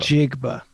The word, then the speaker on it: Njigba: You pronounce the N and then just say Jigba. – Njigba. Njigba.